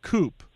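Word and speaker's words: coupé: The car with no accent and a silent E is pronounced coupe.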